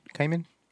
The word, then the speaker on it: Kamen: Who, I hope I said that right. John Kamen? Kamen? – Kamen.